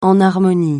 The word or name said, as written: enharmonie